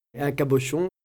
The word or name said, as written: Cabochon